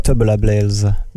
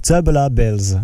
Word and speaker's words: Tubular Bells: Voilà, donc ça s'écrit T-U-B-U-L-A-R. – B-E-2-L-S. Donc voilà. Tubular Bells. Merde, pardon. Tubular Bells. – Voilà, c'est ça.